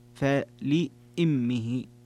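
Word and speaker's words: فلإمه: قراءة الجمهور فلأمه، وتفرد حمزة والكسائي فلأمه بكسر الهمزة، فلأمه السدس.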